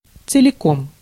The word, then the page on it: целиком: /t͡sɨlʲɪˈkom/